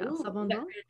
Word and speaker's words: Sauvignon Blanc: Yeah, and I got my Josh Cellar's Sauvignon Blanc. – Did I say that right? – Yeah, Sauvignon Blanc.